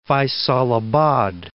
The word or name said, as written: Faisalabad